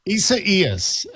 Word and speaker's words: Isaias: Isiasis, is that how do we pronounce that? – Isaias. And I was just talking to Scott about this. Isaias. I don't think I'm putting the pronunciation in quite right when I say it, but that's how you say it.